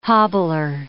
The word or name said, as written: hobbler